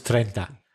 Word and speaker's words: treinta: And it was treinta. Because if it was trece, it would be 13. – So you stress the second syllable, yeah. Next word, through, T-H-R-O-U-G-H, through.